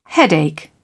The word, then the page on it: headache: British English